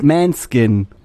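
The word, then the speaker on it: Måneskin: Nein, die Band heißt Manskin.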